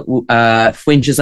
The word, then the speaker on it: Foinse: If you want to find us as well, Fuincha's, and I'm doing F-O-I-N-S-E is how you spell it, and it's pronounced Fuincha.